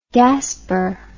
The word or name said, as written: Gaspar